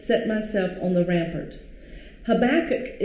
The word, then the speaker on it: Habakkuk: And please forgive me if I do not pronounce Habakkuk. Right. I will try to get that K on the end.